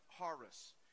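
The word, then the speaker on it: charis (χάρις): This word grace is a Greek word, and it's actually pronounced charis.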